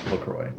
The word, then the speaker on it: LaCroix: On the Wikipedia, it says rhymes with the boys, Lacroix.